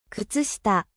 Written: Chaussettes

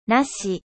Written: なし

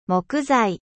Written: 木材

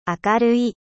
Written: lumineux

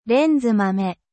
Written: gourgane

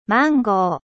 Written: mangue

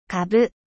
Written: navet

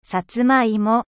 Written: 薩摩芋

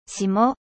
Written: gel

